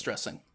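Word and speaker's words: Stresing: Because there's the one S. – I know it is. It's missing the, yeah, it's missing the requisite extra S, but it is pronounced Stressing.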